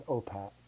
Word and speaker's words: OPAAT: I pronounce it as OPAT.